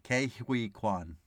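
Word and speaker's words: Ke Huy Quan: Well, I'm so used to saying Ki, but I guess it's K. Kei-Hui Kwan.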